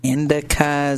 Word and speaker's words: indica: I mean, I've always heard of indica, is that how you say it? – Yeah.